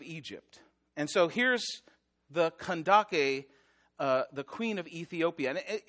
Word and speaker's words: Kandake: Now, this verse has always confused me because in other translations, Kandake is spelled C-A-N-D-A-C-E, which any Full House fan knows, is pronounced Candace, right? But this is, it's actually, even though it's spelled that way, it's pronounced Kandake.